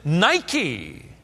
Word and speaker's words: Nike: Nike. We pronounce it Nike. It means victory.